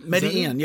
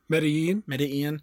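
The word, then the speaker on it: Medellín: Medellin, yeah. So this town is, the estate is outside of, I say it again? – Medellin.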